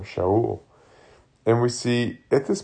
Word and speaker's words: Shaul: Which, that sounds really similar to us, but we actually have a wrong pronunciation. I think Saul is more like Shaul.